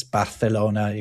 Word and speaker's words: Barcelona: But in Castilian Spanish. Yeah, it is. It's Barcelona. You've got Zaragoza as well. Sevilla, that are pronounced with this th sound.